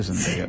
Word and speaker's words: Zendaya: Creo que es Zendaya que Zendaya. Se pronuncia. Me lo enseñaste vos eso. – Sí, ¿viste? Se dice Zendaya.